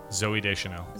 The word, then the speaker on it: Deschanel: Zooey Deschanel.